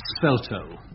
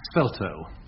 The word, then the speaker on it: svelto: What is the meaning of svelto? I'll spell it if you like. Svelto. – Svelto. – S-V-E-L-T-O.